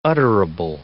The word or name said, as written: utterable